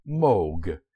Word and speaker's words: Moog: It is not pronounced as Moog. Moog is a sound made by a cow after she painfully stubs her hoof. Despite its double O, the name is pronounced Moog, as in vogue.